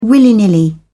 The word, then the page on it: willy nilly: British English